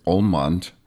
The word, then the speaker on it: Ullmont: Ullmont Lenhart James Jr. Okay, all right.